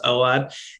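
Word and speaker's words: Ordet: Oward, or sometimes called Or Death, but it's a Danish film, and my Danish friend tells me it's pronounced Oward.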